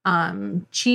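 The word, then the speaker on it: Chih: Vo says, I'm not sure if I'm pronouncing the name of the character right. It's C-H-I-H. Chi. – In the audiobook, they say Chi.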